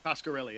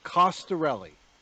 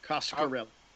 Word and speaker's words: Coscarelli: Um, how do you say his name again? Don Coscarelli. Coscarelli, yeah. Costarelli, Coscarelli. Coscarelli. Yeah. Coscarelli. You got yes. it. All right.